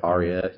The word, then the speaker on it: Arya: And if you got Ira from Game of Thrones, Ira Stark, is that the right way to pronounce it? – Arya, yeah.